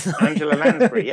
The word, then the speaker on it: Angela Lansbury: Angela Lansbury.